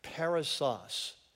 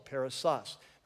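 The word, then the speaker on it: perissos: So the phrase to the full is actually a single word in Greek, and it's the word parousos. It's not an unusual word. So if you want to write it down, it's P-E-R-I-S-S-O-S. P-E-R-I-S-S-O-S, emphasis, accent on the last syllable, parousos, right?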